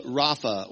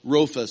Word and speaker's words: Rapha: One of the Old Testament names by which God identifies himself is Jehovah Rapha, or Rofa, some pronounce it.